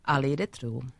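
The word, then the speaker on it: Aliide Truu: Now, how do I pronounce this name? Because it has two vowels in the first name and two vowels in the second name. Alida Tru? – Alida Tru.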